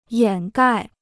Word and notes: 掩盖: yǎn gài

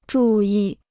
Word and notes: 注意: zhù yì

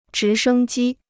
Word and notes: 直升机: zhí shēng jī

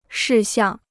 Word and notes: 事项: shì xiàng

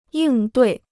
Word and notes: 应对: yìng duì

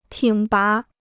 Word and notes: 挺拔: tǐng bá

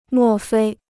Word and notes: 莫非: mò fēi

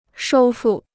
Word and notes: 收复: shōu fù

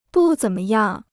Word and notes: 不怎么样: bù zěn me yàng

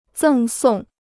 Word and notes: 赠送: zèng sòng

